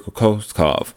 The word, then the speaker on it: Kokoskov: Igor Kokoska. Oh, I'm sorry, I just butchered Igor's name. Igor Kokoskov.